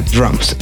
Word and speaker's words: DRMS: Sí, el día de hoy Cristian Verduzco desde Morelia, Michoacán, nos presenta un proyecto que se llama Drums. Se escribe DRMS, son de Monterrey, Nuevo León y creo que les van a gustar.